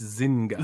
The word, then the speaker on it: Zynga: It's Zynga.